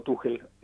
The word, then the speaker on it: Tuchel: Lo que hizo Tuchel el otro día, o Tuchel, así se pronuncia, sí.